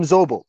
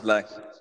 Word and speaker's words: Szoboszlai: I'm going to call him Zobo. He. was Szoboszlai.